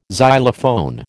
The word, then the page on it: xylophone: American English